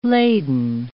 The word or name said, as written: laden